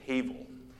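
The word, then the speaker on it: Havel (הבל): The name Abel in Hebrew is actually spelled with an H. It's Havel.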